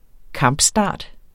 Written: kampstart

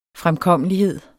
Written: fremkommelighed

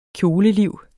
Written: KJOLELIVET

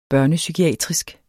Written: børnepsykiatrisk

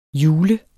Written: julet

